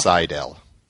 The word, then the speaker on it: Sydal: How do you pronounce his name? Sidal? Sidal? Sidal. Sidal? Yeah. Okay.